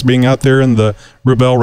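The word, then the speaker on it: Rebelle: But the way I learned to pronounce Rebel is, we all know that rebel is a person who defies convention, and a belle is a beautiful woman. So a rebel is a beautiful woman who defies convention.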